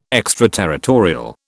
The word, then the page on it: extraterritorial: British English